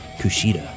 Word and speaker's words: Kushida: I learned how to say his name. Kushida.